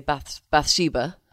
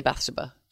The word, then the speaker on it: Bathsheba: I think the Julie Christie version, say Bathsheba, and we say Bathsheba.